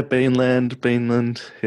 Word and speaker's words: Beanland: Is that how you say it, Beanland? – Yeah, Beanland. Yeah, however the accent says it, it's fine.